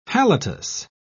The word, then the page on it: halitus: British English